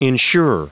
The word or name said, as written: insure